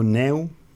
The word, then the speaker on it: Neu: Ça se dit Noi ou Neu. Ça s'écrit Neu, mais... – Ça s'écrit N-E-U.